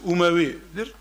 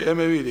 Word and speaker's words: Emevi: Arapçası Umevi'dir. Türkçe'ye Emevi diye telaffuz ediliyor.